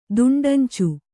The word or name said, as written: ದುಂಡಂಚು